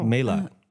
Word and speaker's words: Mélat: Are we pronouncing it right? – No. – Oh. – Mélat. – There we go.